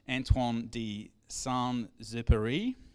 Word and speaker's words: Antoine de Saint-Exupéry: I got Joan, who speaks a bit of French, to write out phonetically how I say this guy's name, because she had to duck off. Antoine de Saint-Exupéry.